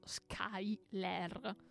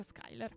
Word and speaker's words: Schuyler: Ora, non fatevi ingannare dalla pronuncia, perché non è scritto Skyler, bensì è scritto S-C-H-U-Y-L-E-R. Oddio, che nome particolare. Schuller. Mm, ok. Però si pronuncia Skyler.